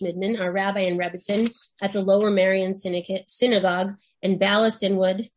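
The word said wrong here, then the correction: Bala Cynwyd: Did I get that right? How do you pronounce that? – Kinwood, but don't worry, it's well. – Kinwood, Valley Kinwood, Pennsylvania, sorry.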